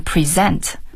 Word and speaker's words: present: 当然另外的话比如说一个英语的单词，比如说 present 是一个礼物拼写的话是 present， present 这个词如果读成 present 就变成展示，对，或是 you know presently 现在啊的这个，所以一个词它的读音不一样的话，其实这整个意思都不一样了，对。